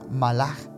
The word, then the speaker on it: malach: Que la palabra en hebreo se pronuncia malach, pero se escribe malak.